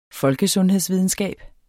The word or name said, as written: folkesundhedsvidenskab